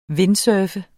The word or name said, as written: windsurfer